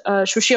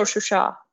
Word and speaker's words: Shusha: And Shushi is Shushi or Shusha, if we go with Azerbaijani pronunciation, is very, very important, you know, culturally for both nations.